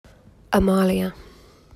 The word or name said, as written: Amalia